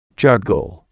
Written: juggle

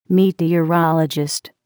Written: meteorologist

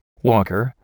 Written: walker

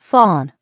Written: fawn

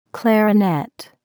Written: clarinet

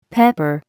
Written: pepper

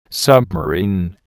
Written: submarine

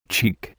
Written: cheek

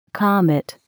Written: comet

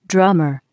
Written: drummer

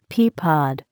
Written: pea pod

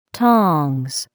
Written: tongs

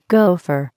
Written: gopher

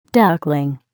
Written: duckling

